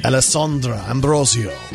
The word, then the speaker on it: Alessandra: Did I say that correctly? Could you please say her name correctly? – Alessandra Ambrosio.